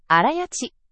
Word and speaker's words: 荒焼: 一つ目が、荒焼き。荒焼ち、と読みます。